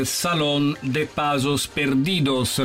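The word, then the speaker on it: Salón de Pasos Perdidos: Salon de Pasos Perdidos. La pronuncia è corretta, Padre Torres? – Corretto. La pronunciación española está facendolo molto bene, sì.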